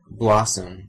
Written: blossom

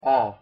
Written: ㅓ

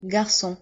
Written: garcon

